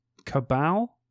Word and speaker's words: Cabal: Two books would be Clive Barker Cabal, C-A-B-A-L.